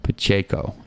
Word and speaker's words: Pacheco: Pacheco.